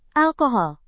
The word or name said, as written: alkohol